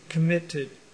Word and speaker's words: committed: And three, the accent is on the last syllable, committed.